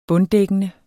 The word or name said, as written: bunddækkende